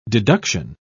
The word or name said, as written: deduction